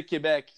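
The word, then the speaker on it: Québec: In French, we say Quebec.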